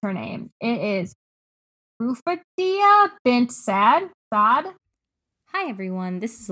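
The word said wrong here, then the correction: Rufaida bint Sa'd: The way meant to pronounce her name was something closer to Rufida bint Saad, but I might not be correct either.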